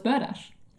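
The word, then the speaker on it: berdache: Oh, well, it's burdash. – Yeah, like, I've heard burdash and I've never seen it written down.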